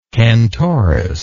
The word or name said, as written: cantoris